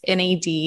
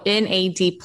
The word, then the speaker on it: NAD+: Not you, how people do, because it's written capital NAD+. Yeah. I've heard it said so many ways. I've even heard it, the way you say it is NAD, the plus is silent.